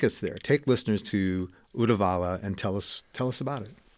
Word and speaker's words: Uddevalla: So where I want to start the interview is in, I don't know how to pronounce it correctly, Uddevalla? – Uh-huh.